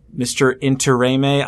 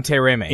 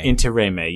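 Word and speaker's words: Intiraymi: Interrame, I can never pronounce his name right, Icheb from Voyager. – Manu Interrame. – Interrame, yes.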